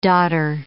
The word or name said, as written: dodder